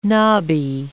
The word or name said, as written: nobby